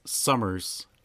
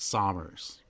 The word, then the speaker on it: Sommers: So my last name is Summers with an O. Mike would pronounce it Sommers.